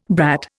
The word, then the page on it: brat: Indian